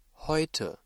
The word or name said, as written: heute